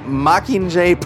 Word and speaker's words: Mockingjay: So this week we saw Hunger Games Mockingjay Part 1.